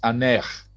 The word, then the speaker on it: Ankh: Now, Anea is the actual pronunciation of what we call Ankh today, the sign of life, the symbol of life. The modern Egyptologists call it Ankh, but actually it was pronounced Anech.